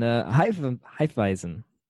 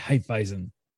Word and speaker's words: Hefeweizen: And Hefeweizen. That's how you say it. – Yeah, Hefeweizen.